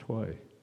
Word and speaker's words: hoy: H-A-Y-A. When it's pronounced Hoy. So why aren't we writing it H-O-Y?